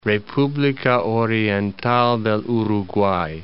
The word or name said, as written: Republica Oriental del Uruguay